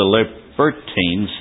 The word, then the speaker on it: Libertines: Then there arose certain of the synagogue, which was called the synagogue of the Libertines," the accent is on the B-E-R, strangely, "and Cyrenians and Alexandrians, and of them of Cilicia and of Asia, disputing with Stephen."